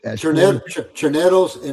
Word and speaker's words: Choneros: Los Choneros and Los Lobos. Hopefully I came close. – Choneros and Los Lobos, yes.